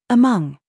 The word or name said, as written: SING